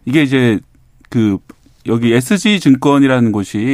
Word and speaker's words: SG: 예, 오늘은 쓰기라고 읽으면 안 되겠네. SG 사태로 본 어, 주가 조작 일대기 살펴보겠습니다.